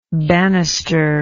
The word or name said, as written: bannister